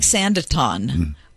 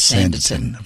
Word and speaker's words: Sanditon: Oh, well, it looks like Sanditon, but the way they pronounce it is Sanditon.